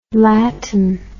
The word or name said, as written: lattin